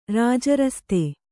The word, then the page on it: ರಾಜರಸ್ತೆ: kannada